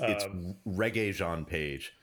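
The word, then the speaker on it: Regé-Jean Page: It's Reggae Jean Page.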